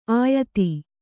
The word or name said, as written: ಆಯತಿ